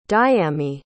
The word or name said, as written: dayami